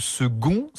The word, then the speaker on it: second: Pourquoi le mot « second » s'écrit avec un « c » alors qu'on le prononce avec un G, comme un G, dites-nous.